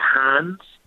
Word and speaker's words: Reims: mm It's spelled R E I M S, but it's pronounced I think Hans.